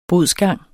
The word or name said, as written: bodsgang